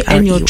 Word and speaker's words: Enyo: And the Tree is T-R-E-Y. – Yeah, E-Y. – Okay, Enyo Tree. – So yeah. – All right. So there you go. So the pronunciation is Enyo. – Yeah, Enyo.